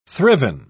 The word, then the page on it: thriven: British English